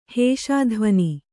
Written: ಹೇಷಾಧ್ವನಿ

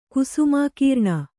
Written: ಕುಸುಮಾಕೀರ್ಣ

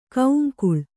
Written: ಕೌಂಕುಳ್

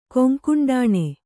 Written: ಕೊಂಕುಂಡಾಣೆ

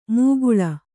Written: ಮುಕುಳ

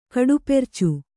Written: ಕಡುಪೆರ್ಚು

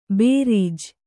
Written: ಭರ್ಜಿ

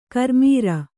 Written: ಕರ್ಮೀರ

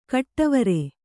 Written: ಘಟ್ಟವರೆ

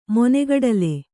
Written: ಮೊನೆಗಡಲೆ

